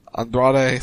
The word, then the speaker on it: Andrade: The Perfect 10, Ty Dillinger took on Andrade Cien Almas.